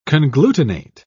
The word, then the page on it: conglutinate: British English